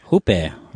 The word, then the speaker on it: Jupe: So this little film, you know, it stars Matt Damon, Julianne Moore, Oscar Isaac, Noah Jupe. I hope that's how you pronounce it. – Hupe. – Right. Exactly. That's exactly his name.